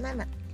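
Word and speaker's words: Nana (七): dibacanya hmm, Nana bukan sici ya Nana untuk nomor telepon Nana uh,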